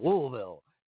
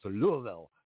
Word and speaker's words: Louisville: So last week we had Lynn Schuessler Williams, who's from Louisville, and she's got that same thing about it's Louisville. Not, you know, and no Chicagoan can ever pronounce it. Not Louisville, yeah.